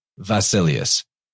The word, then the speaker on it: basileus: Now, in the modern Greek, they would actually pronounce that beta like a V, so it'd be basileus.